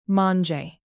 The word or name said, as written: mange